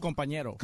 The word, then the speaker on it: compañero: All right, call Pepe, your companero. How do you say it? Companero? – Compañero.